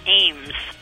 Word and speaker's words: Eames: We're talking to Ed and Tony Eames. – No, it's pronounced Ames. – Oh, I'm sorry. Very good. Ames, like as in Ames, Iowa? – Yes. – Right. – But not spelled that way.